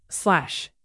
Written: slash

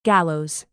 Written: gallows

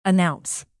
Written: announce

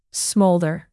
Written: smolder

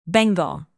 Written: bengal

